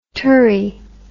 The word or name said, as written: turri